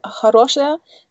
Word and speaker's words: хорошая: Ja, hier wird zum Beispiel auf хорошая, da wird die Betonung auf eta хорошая idea.